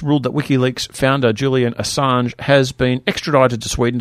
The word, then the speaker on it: Assange: How do you pronounce his Assange. – Name? – Assange.